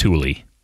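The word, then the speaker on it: Tooele: It's very it's T-O-O-E-L-E, which is obviously it's pronounced Thule, right? It's Thule.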